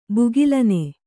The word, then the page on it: ಭುಗಿಲೆನ್: bhugilen